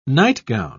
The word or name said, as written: nightgown